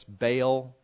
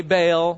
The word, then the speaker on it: Baal: Okay, and I know you've heard your whole life, it's Baal. That's not good Semitic pronunciation. So if you want to sound like a foreigner, say Baal. That's fine. There's actually an unpronounced consonant in between the A and the A, which is why you pause.